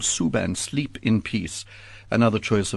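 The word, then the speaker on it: Suo Gân: Is that correct pronunciation? – That's right. Yes. – Suban, Sleep in Peace.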